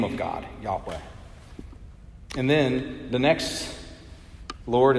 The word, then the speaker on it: Yahweh: Some may say Jehovah, coming out of the pronunciation amongst the Germans, but it's Yahweh.